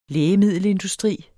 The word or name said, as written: lægemiddelindustri